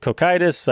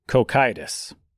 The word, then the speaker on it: Cocytus: Also, a brief note, I'm not entirely sure how you pronounce Cocytus. I've heard it Cocytus, I've heard it Cocytus. In darker days, eventually we're going to get here, I am going to pronounce it as Cocytus. When I'm talking about it for the sake of Dante's poem, I'm going to go with Cocytus.